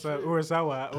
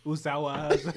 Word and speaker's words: Urasawa: No, I said, yeah, one time I mispronounced Urasawa. What did I say? I said, Usawa.